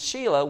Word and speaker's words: Shelah: Now, Shelah is spelt with I and I. It's S-H-E-L-A-H. And so it's Shelah.